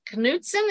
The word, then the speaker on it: Knutson: And I was sent in a story by Melissa Knutson.